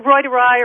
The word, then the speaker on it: reuteri: First of all, the little mice did not have, Lactobacillus rhodii, is that what it was called? – It's pronounced, reuteri or reuteri, yeah.